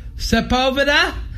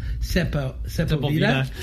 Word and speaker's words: Sepulveda: They said pronounce two things. Sepulveda. Sepulveda. – Sepulveda.